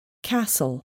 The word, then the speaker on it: castle: The letter T is not pronounced in these common words. Castle, Christmas, listen, whistle.